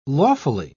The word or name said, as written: lawfully